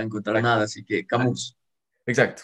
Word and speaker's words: Camus: Bueno, Camus, creo que es, Camus porque es francés. Era un filósofo francés, entonces como los franceses son raros, usualmente las últimas eh, palabras, las últimas letras no se pronuncian, entonces como Camus.